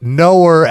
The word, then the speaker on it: Knower: Knower, not the way Thor Schretzing pronounces Noah, but Knower as in I know, I'm someone who knows, Knower, Knower.